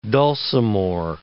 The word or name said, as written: dulcimore